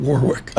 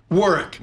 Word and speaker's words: Warwick: I pronounce it that way just to aggravate Pharaoh. – Does it work? – They quote, Warwick. It's the, how do you, work, how do you Warwick. – Say Warwick.